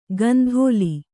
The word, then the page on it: ಕಂದೋಲಿ: kandōli